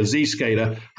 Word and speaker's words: Zscaler: Look, Zscaler, to pronounce it as you corrected me earlier, Zscaler has a wide range of products and services to help deal with encryption and protect businesses.